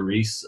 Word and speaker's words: Riis: I'm just Andrew Reese. I'm probably the first Andrew Reese on Facebook. So I think it was just Andrew.Reese. My last name is spelled R-I-I-S.